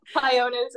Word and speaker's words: Pionus: In Dutch, we say Pionus. – So Pionus. I was like, I don't know if I'm saying this right. – Me neither. I'm just saying whatever sounds English. But Pionus is what I say in English.